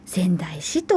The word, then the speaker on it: 川内市: はい、そして、えー、部と書いて部活の部ですね宝部さんです、はい、そして薩摩仙台市の仙台という字は川に内側の内と書いて仙台市と読みます